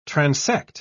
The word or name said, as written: transect